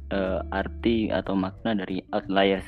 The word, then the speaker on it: Outliers: Judul bukunya agak agak susah nih pronounsnya saya nggak tahu apa cuman saya coba searching di Google Translate ternyata bacanya outliers. Betul gak? – Iya, betul betul.